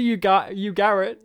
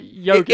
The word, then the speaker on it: Ugarit: Was it Ugarit? – Ugarit. – Ugarit. – Yeah. – Not yogurt.